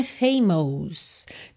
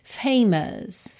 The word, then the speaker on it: famous: Aqui é muito interessante a pronúncia de famous, que não é famous, tá? Embora nós tenhamos M-O-U-S, a gente não pronuncia mos, e sim famous, famous, ok?